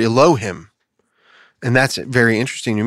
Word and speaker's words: Elohim: Pronunciation is Elohim.